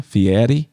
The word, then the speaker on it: Fieri: I'm so much of a fan that I try to say his name correctly, Fieri.